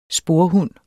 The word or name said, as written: sporhund